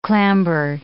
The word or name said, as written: clamber